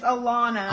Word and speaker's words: Alona: It's Alona. – Alona, okay. Because if you take the A off, you wouldn't say Lona, you would say Lana.